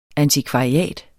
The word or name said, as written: antikvariat